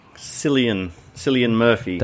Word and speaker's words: Cillian Murphy: Is it Cillian or Killian or Kialan? – Cillian Murphy.